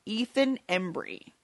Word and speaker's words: Ethan Embry: Ethan Embry.